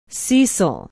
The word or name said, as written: cecil